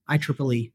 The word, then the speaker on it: IEEE: So it's the acronym is IEEE. That's how we like to pronounce it.